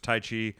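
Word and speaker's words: Taichi: Well, that brings us John Moxley versus Tai Chi. I'm pronouncing that right. – Yeah.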